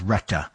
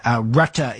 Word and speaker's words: Rutte: And just remember, currently NATO Secretary General Mark Rutte, I think it's pronounced Retta, welcomed the remarks.